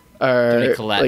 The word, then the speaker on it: Toni Collette: But, like, I mean, a movie like Hereditary, if not, like, Best Picture looks, I mean, however you say her name, Toni Collette? – Toni Collette.